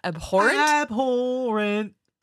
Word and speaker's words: abhorrent: Abhorrent. Yeah. Yep. – The stress is on the second syllable. Abhorrent.